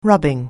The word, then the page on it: rubbing: anglais